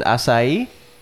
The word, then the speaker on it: acai: Like in a similar, the acai, is that how you say it? – Acai.